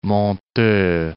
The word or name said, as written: Monteux